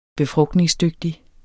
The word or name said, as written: BEFRUGTNINGSDYGTIG